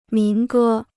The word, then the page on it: 民歌: mín gē